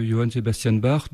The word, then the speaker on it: Johann Sebastian Bach: Vous venez d'entendre, chers auditeurs, toujours sur RCF, dans notre émission sur les basses renaissances, la chaconne de Johann Sebastian Bach, devrais-je dire pour le prononcer un petit peu plus à l'allemande.